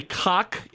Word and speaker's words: Koch: Did you say Koch Industries? – Yeah, K-O-C-H, Koch Industries. – Oh, I didn't know how that was pronounced. I appreciate your pronunciation on that.